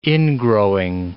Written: ingrowing

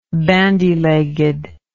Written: bandy legged

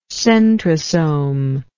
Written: centrosomic

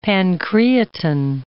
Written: pancreatin

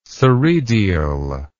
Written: soredial

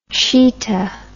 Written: chetah

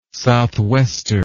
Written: southwester

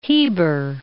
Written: Heber

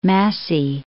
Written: Massey

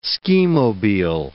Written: skimobile

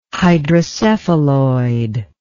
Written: hydrocephaloid

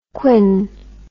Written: quin

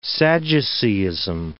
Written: Sadduceeism

